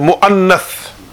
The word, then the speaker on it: mu'annath: So to pronounce it is mudhakkar, and feminine mu'annath.